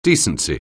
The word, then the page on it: decency: anglais